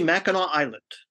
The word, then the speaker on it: Mackinac: And I told her specifically, I said, now understand that there's Mackinac City, which is spelled N-A-W at the end. And there's Mackinac Island, which is spelled N-A-C at the end. So they're both pronounced the same with different spellings. It's very strange. But that's how it is. So it's actually Mackinac Island, and it is a crown jewel of vacation spots in Michigan.